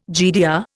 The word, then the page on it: GDR: Indian